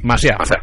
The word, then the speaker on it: Macià: Lo he dicho Macià. De aquella manera, es Francesc Macià. Con acento en la. – Sí. – Macià.